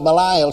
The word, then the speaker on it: Belial: We call it Belial sometimes. Actually, the correct pronunciation is Belial.